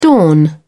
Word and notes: dawn: British English

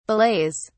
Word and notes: belays: British English

